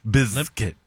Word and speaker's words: Bizkit: I've never heard someone pronounce the Z so hard. – Yeah, I was like visualizing it in my head. Because we just say biscuit, but I mean, it really is Bizkit.